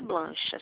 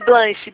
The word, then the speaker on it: Blanche: Brancha, é não. – Blanche, sei. Blanche. – Blanche, meu Deus, pronuncia. – É Blanche, B-L-A-N-C-H, tá?